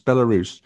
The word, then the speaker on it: Belarus: Belarus. – Oh, I thought it was a silent S. Belarus.